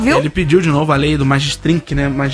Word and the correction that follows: Magnitsky: Como é que fala isso? – Quê? – Magnitsky. Ele pediu de novo a lei Magnitsky para o Xandão.